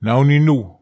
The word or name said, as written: naonin nu